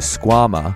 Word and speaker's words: Squama: The Squama, or rhymes with your mama, is a high-performance Velcro slipper aimed at getting you boulderers up your sickest project.